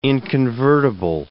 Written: inconvertible

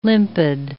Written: limpid

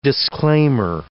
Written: disclaimer

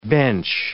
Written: bench

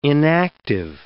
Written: inactive

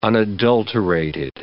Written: unadulterated

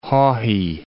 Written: Haughey

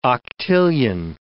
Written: octillion

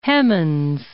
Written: Hemans

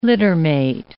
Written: littermate

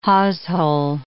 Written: hawsehole

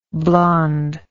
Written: blondeness

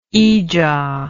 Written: ijaw

